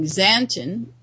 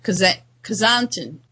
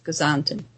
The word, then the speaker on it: Xanten: You know, I learned finally how to pronounce what I was calling Xanten, X-A-N-T-E-N, which, of course, is Kazanten, Kazanten. That X is like a K-S, Kazanten.